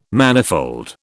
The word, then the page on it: manifold: British English